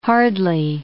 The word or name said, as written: hardly